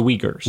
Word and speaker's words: Uyghurs: The Uyghurs. Uyghurs.